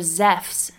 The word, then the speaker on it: Zeus: Zeus, or Zephs as his name is pronounced in Greek, is probably the most well-known of the ancient Greek gods.